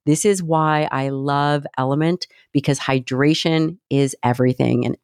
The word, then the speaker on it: LMNT: It is spelled L-M-N-T and pronounced Element.